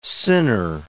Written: sinner

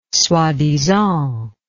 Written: soi-disant

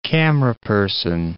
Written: cameraperson

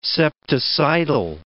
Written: septicidal

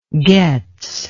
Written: getz

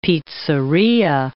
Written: pizzeria

